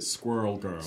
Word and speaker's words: Squirrel Girl: Or, as the Americans pronounce it, Squirrel Girl. – Squirrel Girl. – So the whole thing is wrapped up in this rhyming pun that does not work outside of America.